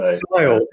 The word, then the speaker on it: Siadhal: And yeah, you did not pronounce that name right at all. It's Sile. So it's a strange jumble of letters, but it's pronounced like Kyle but with an S. So Sile.